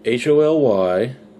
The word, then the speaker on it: Holyroodhouse: Now that's spelled HOLYROODHOUSE. Similar to Hollywood. Holyrood House.